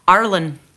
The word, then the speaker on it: Ireland: Because I live in Ireland, or as it's pronounced here, Arlen. – Arlen. No D, right?